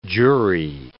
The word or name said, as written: jury